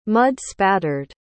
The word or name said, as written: mud-spattered